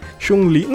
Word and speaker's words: Chun-Li: Chun-Li.